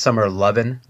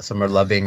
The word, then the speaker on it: Summer Lovin': Scott, I think it's pronounced Summer Lovin', not Summer Loving. I don't think there's a hard G on that.